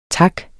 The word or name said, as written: tak